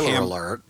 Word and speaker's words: Hamburg: A woman named Barbara Hamburger... The family's name is Hamburg. Hard G. End of word.